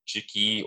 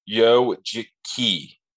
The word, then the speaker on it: Ujiki: and I would think that's like Ujiki or, you know, so I looked it up and it's Yo, the U is pronounced as a Yo, Yojiki, right.